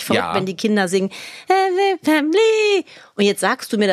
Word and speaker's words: Family: Family, das wird einfach nicht ausgesprochen, das I. Außer in Liedern oder in Gedichten, wie zum Beispiel in dem schönen, auch dir schon lange bekannten Lied Running in the Family.